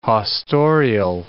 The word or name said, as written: haustorial